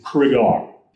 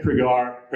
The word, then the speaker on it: Cregar: Other thing to point out here is that the name is Cregar. He always used to tell people it's Laird Cregar, pronounced as if you're saying cigar. So that's the uh, clue to remembering how to say his name.